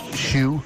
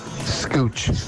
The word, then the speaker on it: Schuh: For years, I used to call the shop that's Shoe. Scooch. – S-C-H-U-H.